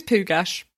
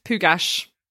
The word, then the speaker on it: Pugach: His name is Pugach. – Okay. Pugach. Yes. – Yeah, I looked up the pronunciation, double checked it. It is pronounced Pugach.